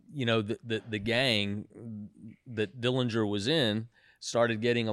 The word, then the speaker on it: Dillinger: But I really can't answer you as to why the fascination, other than, you know, just Dillinger.